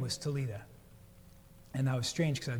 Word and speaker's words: Talitha: Talitha Kum is that phrase we read it, but it's pronounced Talita was her name.